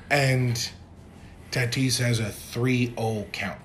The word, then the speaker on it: Tatis: Tatis, is that mm-hmm. how you say it? Tatis Jr. Fernando Tatis. Yeah.